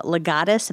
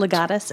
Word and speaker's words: Legatus: I mean, I'm looking at a list that I have right here, everything from Smart Columbus to sports medicine over at OSU, Legatus, if I'm saying that correctly, is that right? – That's correct. – Legatus, okay.